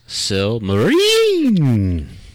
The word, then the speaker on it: Silmarillion: It's pronounced Silmarillion. – Yeah, no, you're missing out like two L's right there. – Silmarillion. – Yes